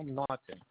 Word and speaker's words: Naughton: It's Michael Naughton. And that's spelled N as in Nancy, A-U-G-H-T-O-N.